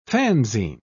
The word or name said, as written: fanzine